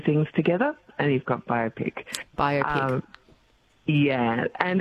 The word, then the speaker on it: biopic: How do I pronounce it first up? Biopic or biopic? – Biopic.